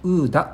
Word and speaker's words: OODA: して意思決定をする時の手順ですよねその方法について、えー、提唱したというやり方ですウーダループっていうんですが、はい、ウーダはねアルファベット 4 つです OODA これでねウーダって読むんです